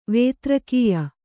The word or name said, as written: ವೇತ್ರಕೀಯ